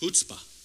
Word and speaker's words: chutzpah: It's not pronounced chutzpah. It's pronounced chutzpah. Chutzpah. Chutzpah, it's a Hebrew word, chutzpah. You kind of got to say it with a little, when you see a Hebrew word and it starts with CH at the beginning, you got to chutzpah.